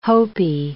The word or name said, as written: Hopi